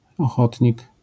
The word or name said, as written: ochotnik